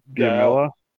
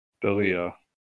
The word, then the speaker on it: D'Elia: Chris, how do you say his last name? – D'Amella? – D'Elia.